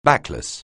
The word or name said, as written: backless